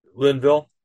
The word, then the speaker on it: Linville: It's Levine, right? The last name is Levine. Did I pronounce that right? Dr. Linville.